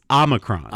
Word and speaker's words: Omicron: In episode 172, Pepper Makes Your Pecker Hard, we debated about how to pronounce Omicron or Omicron. – Yeah. – It is Omicron. – Omicron. – Omicron. – Not Omicron. – No. It's Omicron.